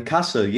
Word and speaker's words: Kassel: Yeah, because I did see there was a protest last weekend in, now forgive me if I pronounce this wrong, is it the city of Kassel? – Kassel, yeah. – Kassel, that was perfect. Very good, yeah.